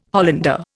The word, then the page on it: Hollander: Indian